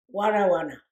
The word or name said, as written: wana wana